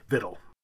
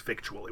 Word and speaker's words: victual: But that did not change the pronunciation. It stayed vittle.